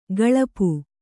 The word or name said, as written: ಗಳಪು